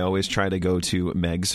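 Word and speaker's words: Meigs: they talk about that it's pronounced Megs because the guy's last name was Megs and it rhymes with eggs.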